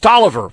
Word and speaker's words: Taliaferro: And he spelled his name Taliaferro, but it's pronounced Tolliver, from what I was later told.